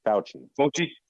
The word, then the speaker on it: Fauci: It seems like he didn't really, when someone says something that is highly regarded, and I'm going to probably say his name wrong, but Fauci, the scientist Fauci. – That he had. – Fauci. – Fauci, there we go.